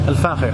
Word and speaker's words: Al Fakher: How would you pronounce F-A-K-H-E-R? Fakher? – Al Fakher.